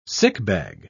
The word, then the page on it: sickbag: British English